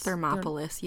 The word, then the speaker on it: Thermopolis: Thermopolis, yeah.